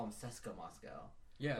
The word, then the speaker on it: CSKA: I've heard people say Seska. – Seska. Yeah. – Okay. That's how you say it.